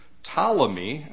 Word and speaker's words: Ptolemy: Ptolemy, and I'll spell that for you too, P-T-O-L-E-M-Y, Ptolemy, the P is silent, became the king of Egypt.